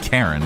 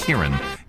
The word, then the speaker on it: Kyren: The top 5 for boys, Iziel, I-Z-A-E-L, Iziel. Chosen. Aiden, E-I-D-E-N, Cassian, C-A-S-S-I-A-N, and Kieran. That's not Karen, is it? It's Kieran. K-Y-R-E-N. The top 5 for girls. Khalil. K-A-E-L-I.